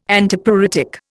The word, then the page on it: Antipruritic: Indian